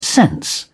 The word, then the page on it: sense: British English